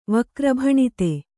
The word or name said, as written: ವಕ್ರಭಣಿತಿ